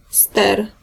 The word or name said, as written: rudder